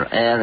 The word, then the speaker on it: Ur: And indeed, the Egyptians said that their gods have come to Egypt through the Red Sea from a place called Urta. Urta is taken to mean the ancient or the olden place, but the name Ur, or Er as it many pronounce it,